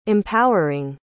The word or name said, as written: empowering